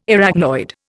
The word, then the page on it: Arachnoid: Indian